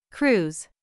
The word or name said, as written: cruise